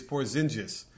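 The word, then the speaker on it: Porziņģis: They were telling me that the correct pronunciation is Porzingis.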